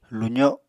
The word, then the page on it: luzo7: Apatani